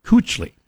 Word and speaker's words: Kuechly: And for those of you who have only seen the name in print, it's Kuechly. I mean, you pronounce it like you did, but it looks like Kuechly.